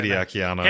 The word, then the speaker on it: Hideaki Anno: Hideaki Anno.